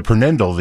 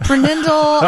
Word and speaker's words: PRNDL: And the Prenindle, the P-R-N-D-L, right above the automatic shifter, she called the Prenindle.